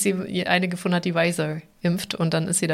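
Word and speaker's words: Pfizer: Ähm, Weiser, so spricht man das aus, oder? – Ja.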